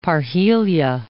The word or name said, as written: parhelia